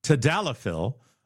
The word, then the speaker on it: tadalafil: I'm not sure the details on this story, other than the FDA tested samples and found a drug called tadalafil.